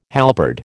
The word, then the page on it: halberd: American English